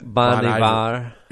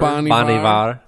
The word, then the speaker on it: Bon Iver: Bon Iver. – Is it Bon Iver? – Bon Iver.